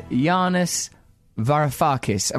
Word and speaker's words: Yanis Varoufakis: My guest today is Yanis Varoufakis.